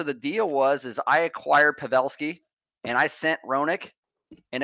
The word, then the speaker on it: Pavelski: Yeah, I will fix you on the pronunciations here, JT, always. But hey, no problem, buddy. We got Pavelski for Dallas. – Pavelski, thank you.